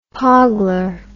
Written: poggler

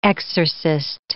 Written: exorcist

